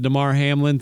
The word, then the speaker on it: Damar Hamlin: I'm not on the conspiracy train that because of the Damar Hamlin thing.